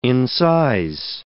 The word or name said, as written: incise